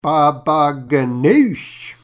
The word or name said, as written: Baba ghannouj